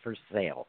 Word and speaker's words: Versailles: They don't say it like the French palace. They say Versailles.